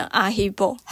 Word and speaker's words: Haribo: Haribo，可是它不是最好吃的，因为法文发音变成阿希波。